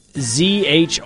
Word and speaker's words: julienne: Yeah, I looked up the pronunciation, and it says Zhrooleen is the pronunciation. But it's spelled out like Julienne.